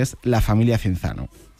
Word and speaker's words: Cinzano: Cinzano en 1940. Y este apellido, pronunciado italiano, quizá no nos suene tanto, pero si decimos Cinzano, nos va a sonar a todos muchísimo más.